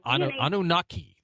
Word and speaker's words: Anunnaki: My question actually is about the Anunnaki. I never pronounce it right. – Anunnaki.